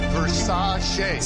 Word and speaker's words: Versace: Pronounced Versace.